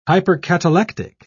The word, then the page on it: hypercatalectic: British English